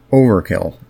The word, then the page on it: overkill: /ˈəʊ.vəˌkɪl/; /ˈoʊ.vɚˌkɪl/